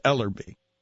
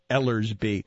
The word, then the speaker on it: Ellerby: That particular player, we're not talking about the middle linebacker, and I always pronounce his name with an S when I shouldn't because I'm an old man. It's Ellerby. It's not Ellersby.